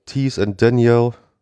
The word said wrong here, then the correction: Denial: Ja, ich glaube, Denial sagt man.